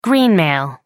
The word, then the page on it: greenmail: American English